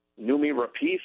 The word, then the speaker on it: Noomi Rapace: Noomi Rapace, is that how you pronounce it? – Yeah.